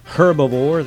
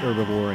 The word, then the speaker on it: herbivore: That's right, I said herbivore. That's how Lindsay Nixon, the happy herbivore, pronounces it. And you'll hear in the interview where I tried to pronounce it herbivore and got smacked down. Well, I didn't get smacked down by Lindsay. I got smacked down by the reality of hearing her say the phrase.